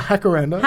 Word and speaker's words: jacaranda: But I like how they pronounce it, the Spanish pronunciation, because their J's are H's. Or... Yeah, she says it with a H. Like she's a Jacaranda.